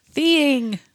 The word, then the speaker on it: theine: Theeing. Yeah. – Oh. – It's the caffeine found in tea. – Oh. – But it's spelled T-H-E-I-N-E, but it's pronounced like thee-ing.